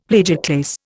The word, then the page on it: plagioclase: Indian